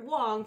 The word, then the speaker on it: Wang: Actually, I realize uh, we have to stop making Wang jokes because that is not how Garrett Wong pronounces his name.